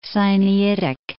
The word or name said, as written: Sinaitic